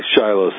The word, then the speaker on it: Shiloh: You say Shiloh. – I'm right? – Proud of you. or referencing a Civil War battle. – Right. Exactly. – But anyhow. We'll say Shiloh so that all the Americans listening will understand.